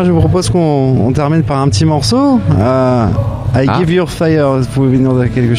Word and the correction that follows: I Give You Five: Comment t'as prononcé ça? – Vas-y, on se casse. Le brouillard ou quoi? – Ouais, un peu, ouais. Comment tu le prononces? – I give you five. – Ouais, I give you five, ouais, voilà.